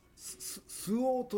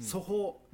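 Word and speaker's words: 蘇芳: スオウという色があるんですね、うんうん、ソホ、うん、どんな阿蘇山のソにね、うん、よしで、うんうんうん、スオウと読むんですけども、うんうん、それをまああえてね、うん、ややこしいんで、うん、ソホと読んだり、うん、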